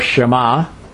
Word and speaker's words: Shema: Its core is Deuteronomy 6:4, the Shema, or Shema. Probably a better way to pronounce it.